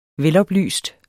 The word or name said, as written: veloplyst